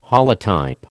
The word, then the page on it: holotype: American English